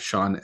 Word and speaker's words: Shaun: It's at Shaun L. D. Cook. And my name is spelled Shaun and Cook with an E on the end.